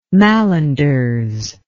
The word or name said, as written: mallanders